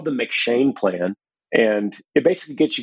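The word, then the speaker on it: M'Cheyne: Yeah, it is M-C, it's Irish. Let me make sure that I'm saying it right. Yeah, it's M-C-H-E-Y-N-E. – Got it, okay. – It's the McShane Plan, and it's just a really good plan, and it's a great addition to my miracle morning.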